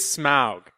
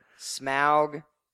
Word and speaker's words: Smaug: It is Smaug. – Smaug, Tauriel, etc.